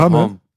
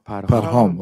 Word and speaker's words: Parham: Weißt du, wie er auf Persisch ausgesprochen ist? Ich hatte den Namen noch nie gehört. Ne? Parham. Parham? Parham.